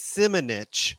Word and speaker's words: Simonich: which is Simonich, which kind of sounds like a kind of a curse word.